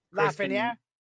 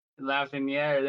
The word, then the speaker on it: Lafreniere: I'm sure you guys heard of him, Alexis Lafreniere. – Lafreniere, there you go.